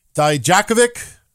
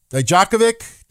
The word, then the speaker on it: Dijakovic: Now, I am proud to let everybody know that I now pronounce his name correctly. All right. You know, I was having a hard time pronouncing his name, but it's Dominik Dijakovic. Dijakovic. Do I say it right now?